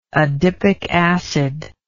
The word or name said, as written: adipic acid